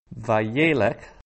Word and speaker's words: Vayelech: Vayelech. Notice the accent there is on that second last syllable, the penultimate syllable.